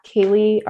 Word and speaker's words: Kaely: It's at Kaely RD. And my name is spelled weird. It's K-A-E-L-Y and then RD.